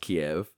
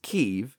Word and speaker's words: Kyiv: And I learned the truth is that in Russian it's pronounced Kiev, in Ukrainian it's pronounced Kyiv.